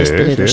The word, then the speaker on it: esteleros: Dice Inma, en Canarias a los componedores de huesos se les llama esteleros. ¿Lo he dicho bien? Sí, sí, esteleros, sí, esteleros, sí.